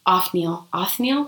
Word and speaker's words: Othniel: Othniel.